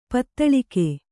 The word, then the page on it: ಪದುಳಿಕೆ: kannada